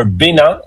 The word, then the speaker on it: Bénin: But first, today we're headed to Benin, or Bénin, as it's pronounced in French, a country in West Africa with deep history and powerful connection to the Black diaspora.